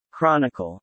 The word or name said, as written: Chronicle